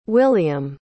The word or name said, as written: wiliam